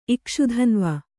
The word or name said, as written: ಇಕ್ಷುಧನ್ವ